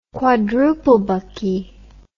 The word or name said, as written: quadruplebucky